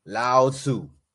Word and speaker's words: Lao Tzu: Lao Tzu. – I like that. I think you pronounced it right too.